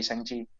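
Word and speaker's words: Shang-Chi: Baca, bacanya Sangchi.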